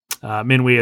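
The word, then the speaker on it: Minuit: Minui is midnight in French. That's how I had that pronunciation so readily available.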